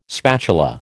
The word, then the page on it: spatula: American English